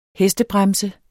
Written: HESTEBREMSER

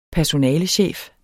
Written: personalechef

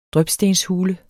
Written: drypstenshule

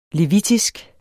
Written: levitisk

